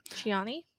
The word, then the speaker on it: Cioni: I don't know if I'm saying it right. C-I-O-N-I. Al Kioni Ford. Did I say it? Anyway, Laura there. And anyway, Chiani.